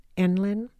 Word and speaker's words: ENLIN: Did you notice that my pronunciation, Enlin, of the acronym I use for near life experiences narratives, rhymes with gremlin?